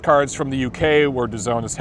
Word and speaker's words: DAZN: They have a streaming service, DAZN, it's pronounced DAZN, it's out of the UK.